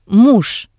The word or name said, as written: muj